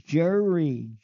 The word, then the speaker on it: Jerry: "Okay, Jeremy." "Huh? My name is Jerry. Jerry. Jerry. See, it's not hard to say my name.